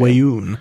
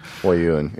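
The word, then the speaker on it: Weyoun: Wayun. – Wayun, yeah.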